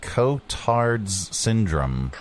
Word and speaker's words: Cotard's syndrome: Cotard's syndrome.